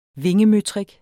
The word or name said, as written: vingemøtrik